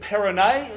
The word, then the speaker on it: Perronet: There is Edward Perronet, as it's pronounced.